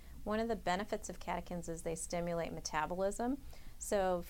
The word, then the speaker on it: catechins: Catechins.